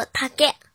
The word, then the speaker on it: 竹: 和小一一起学日语，第八十二课今日汉字“ 竹林” 的“ 竹” 在日语当中，它有音读和训读两种读法。音读的时候读作“ 七个七个七个”，比如“ 竹林”“ 七个林”“ 七个林”“ 七个林”，写成日语汉字也是“ 竹林”。训读的时候读作 “take take take”，比如高跷 “take uma take uma take uma”，写成日语汉字是“ 竹加马” 的繁体字 “take uma take uma take uma”。